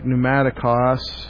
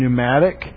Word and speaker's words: pneumatic: P-N-E-U-M-A. In English, we don't pronounce the P in pneumatic, but in Greek they would have. They did pronounce the P. They would have said pneumatic.